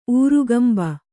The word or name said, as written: ಊರುಗಂಬ